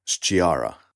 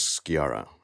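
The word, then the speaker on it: Sciarra: Funnily enough, when it's written in this way in Italian, you would pronounce this then as Sciara, but it's always pronounced as Sciara.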